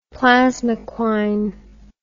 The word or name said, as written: plasmoquine